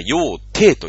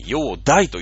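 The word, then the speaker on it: 煬帝: えー、その時、隋を治めていたのが、まあ、陽大というね、えー、これは陽帝と読まずに、陽大と読むところが、まあ、ポイントなんですけれども、まあ、あんまりいい帝じゃない。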